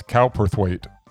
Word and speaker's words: Cowperthwaite: Is that how it's pronounced? Halperthwaite? Halperthwaite? Or is it Cowperthwaite? – I'd say it's Cowperthwaite.